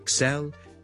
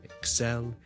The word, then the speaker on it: excel: 个重音呢其实呢佢应该放喺 the second syllable 嘅。 So, excel, excel, excel。